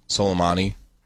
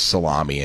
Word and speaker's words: Soleimani: I heard his name pronounced salami a handful of times this week. – That's ridiculous. – By, like, a number of people. – Really? – Yes. – By news people? – Yes. – Prominent people? – I heard salami. I heard people saying salami, and I was like, that's not right.